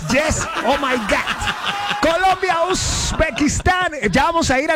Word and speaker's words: Uzbekistán: Pues, a ver, Katy, ¿cómo sería Uzbekistán? – Así es.